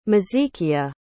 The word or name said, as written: mezekia